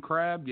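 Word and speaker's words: Crabbe: Is it Crab with a silent E on the end? – Alan Crab, yes.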